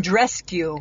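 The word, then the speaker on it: Andreescu: Andrescu. – Andrescu.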